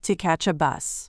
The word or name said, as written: to catch a bus